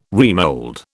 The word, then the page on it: remould: British English